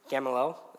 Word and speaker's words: Gamaliel: But a Pharisee from the council named Gamaliel, is that how you say it, Grant? Yeah, that's how he says it.